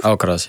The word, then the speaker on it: Alcaraz: Want je staat ook wel eens tegenover Alcaraz. Nou, zeg ik het goed? – Alcaraz, ja.